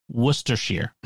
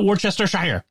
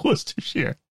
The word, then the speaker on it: Worcestershire: It's pronounced Worcestershire. Not Worcestershire. It's Worcestershire.